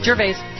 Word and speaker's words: Gervais: Ricky Gervais, am I saying that right? – Gervais.